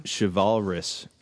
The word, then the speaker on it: chivalrous: Chivalrous. Is that how you pronounce it? – Yeah, that's it. That's the pronunciation.